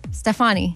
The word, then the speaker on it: Stefani: I said Gwen Stefani weird. – How do you say it? – Stefani.